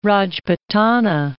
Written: Rajputana

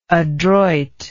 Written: adroitness

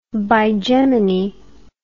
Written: bigemony

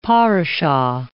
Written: parashah

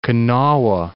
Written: Kanawha

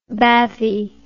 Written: bathy

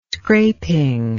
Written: scraping

